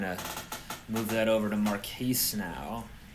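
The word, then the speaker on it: Marquese: Is it Marquise or Marcus? How do you pronounce it even? – Marquise.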